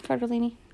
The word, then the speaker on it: Cardellini: I don't ever say her name right. Whatever. – Yeah, Cardellini.